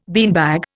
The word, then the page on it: beanbag: Indian